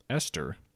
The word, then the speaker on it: Eostre: Some cultures pronounce it Esther. – Yeah. – Even though it's spelled E-O-S-T-R-E.